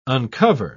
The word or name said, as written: uncover